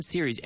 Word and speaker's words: series: S-E-R-I-E-S. Right? So this is, it's a series. One series. I watched one series. S-E-R-I-E-S. So don't think it's plural. Don't try to say Siri. The first Siri. No. Series. Always. One series. And 100 series, S-E-R-I-E-S. So that's the way it's pronounced, okay?